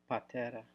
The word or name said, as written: Patera